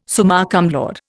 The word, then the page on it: summa cum laude: Indian